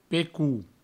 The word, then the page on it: pecol: pecou; provencal